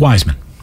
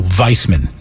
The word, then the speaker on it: Wiseman: It's Wiseman. – I don't think so. It's been Wiseman since the day he was drafted. Everyone in the world says Wiseman, including him. Why do you call him Weissman? – I don't call him Weissman.